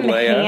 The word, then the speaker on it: Leia: There are, like, differences in pronunciation. Some said Leah, some said Leia.